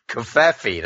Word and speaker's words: Covfefe: Covfefe, that's how you say it.